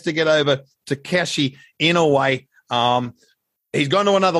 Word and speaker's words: Inoue: I think I've said Inoue, by the way, four different times oh, with four different pronunciations. Inoue. – Yeah. – It's Inoue. Inoue. No relation to the monster, but it's Inoue. uh,